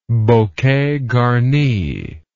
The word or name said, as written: bouquet garni